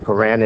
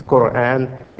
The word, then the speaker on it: Quran: And Quran, some pronounce it Quran. In English, Quran. It's the Quran.